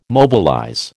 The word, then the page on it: mobilize: American English